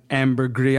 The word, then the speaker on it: ambergris: Apparently, a serving of eggs and ambergris, I'm going to go with the French pronunciation, was reportedly King Charles II of England's favorite dish.